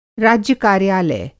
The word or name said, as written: राज्य कार्यालय